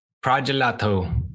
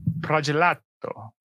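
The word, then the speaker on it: Pragelato: Pregelato. How do you say it? – Pregelato. – Pregelato. Yes.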